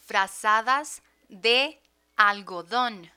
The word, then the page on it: frazadas de algodon: Spanish